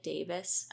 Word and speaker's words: Davies: He's played by is Greg Davies and or Davis. It's spelled like Davies, but they pronounce it like Davis.